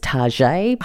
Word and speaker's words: Target: Sorry, Larissa, I think the correct pronunciation is Target, but we'll move past that.